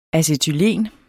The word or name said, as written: acetylen